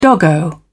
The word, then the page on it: doggo: British English